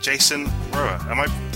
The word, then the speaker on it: Jason Rohrer: We are joined today by creator of Passage, a number of fantastic indie games, and recently released SleepisDeath, Jason Rohrer.